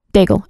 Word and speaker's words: Daigle: Daigle. – Okay. – Daigle, like bagel. – Okay. – But with a D.